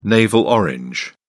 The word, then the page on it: navel orange: British English